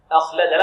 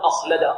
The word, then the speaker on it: أخلده: هنا أخلده، لا نقول أخلده لا هذا خطأ، بل نقول أخلده تنطق بالهمزة مفردة بعد ذلك تأتي بها مع الخاء، لأنه كما يقول علماء التجويد يحدث في التركيب ما لا يحدث في ماذا؟